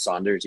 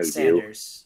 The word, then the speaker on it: Sanders: And Spencer Sanders, is it pronounced Saunders, even though there's no Sanders. – U? Sanders. Yeah, okay.